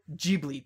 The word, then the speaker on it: Ghibli: I remember it's called Studio Ghibli. That's how you pronounce it.